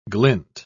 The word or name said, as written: glint